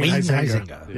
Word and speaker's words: Huizenga: That was on orders of Huizenga, is that how you say it? – Wayne Huizenga. – Yeah.